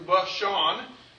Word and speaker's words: Bashan: That's how you pronounce it in Hebrew, Bashan.